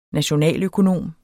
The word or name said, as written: nationaløkonom